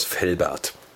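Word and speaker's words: Velbert: Venoa79 kommt aus Felbert. Felbert schreibt man mit V. Und ist hinten wie Bert. Wie Arnie und Bert.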